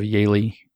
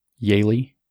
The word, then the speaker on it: Yaeli: You can look up the story of Yaeli Galdamez if you want. That's one example I bring up a lot. It's a tragic story. Immigrant mother comes up from Peru. Her daughter, Yaeli, that's Y-A-E-L-I, if you want to look her up.